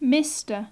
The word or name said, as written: mr